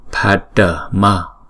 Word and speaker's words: padma: Nếu mà chúng ta đọc thành ba âm như là Padma là không đúng ha. Padma, Padma ha.